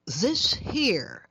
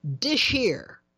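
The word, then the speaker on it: Dis Here: Cannonball Adderley and a bunch of really great sidemen with something called This Here, except he cautions it's not to be pronounced that way. It's supposed to be pronounced Dish Here.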